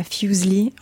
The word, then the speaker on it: Fuseli: Fuseli. Donc, il y a eu un dérivé orthographique. Donc, on est passé de Fuseli à Fuseli en Angleterre.